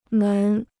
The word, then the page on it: 门: mén